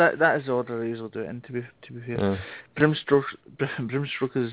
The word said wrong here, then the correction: Bram Stoker: is Dracula. – Yes. – Or Bram Stoker, how you say it. – Bram Stoker.